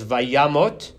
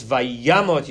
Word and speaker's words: vayamot: This does not that the kamatz under the mem is a kamatz katan because the stress is on the yud, so it's vayamot. It's vayamot Yosef ben me'av eser shanim do vayisem ba'aron b'mitzrayim.